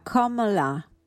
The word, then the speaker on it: Kamala: nome si pronuncia Komala, significa fiore di loto, che è un simbolo importante nella cultura indiana.